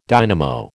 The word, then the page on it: dynamo: American English